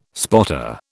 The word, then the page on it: spotter: British English